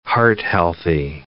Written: heart-healthy

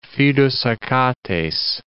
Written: fidus Achates

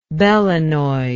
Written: belonoid

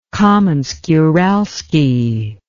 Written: kamensk-uralski

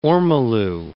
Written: ormolu

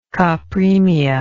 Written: copremic

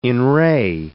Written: in re

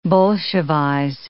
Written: bolshevize

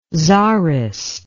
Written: tsarist

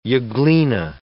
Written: euglena

